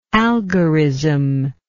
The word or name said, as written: algorism